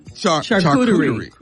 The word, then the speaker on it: charcuterie: Yeah, how you say it? – Charcuterie. – Okay, charcuterie.